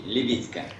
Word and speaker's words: Лебедько: Правильно ударение? Лебедько, Александр. – Анатолий Лебедько. – Вот Анатолий Лебедько.